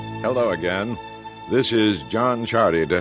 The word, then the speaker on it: Ciardi: Chardy. It is now an Italian name, and it is spelled C-I-A-R-D-I.